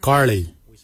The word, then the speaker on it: giilyi: Dab is spelled D-H-A-A-B. It is a traditional knife, a dab. For boomerang, we say karli. Karli is the traditional name for boomerang. Karli is spelled G-I-I-L-Y-I, karli.